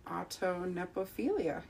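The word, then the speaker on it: autonepiophilia: Audio nepophilia? Did I pronounce that right? – Autonepophilia. Yeah, good job.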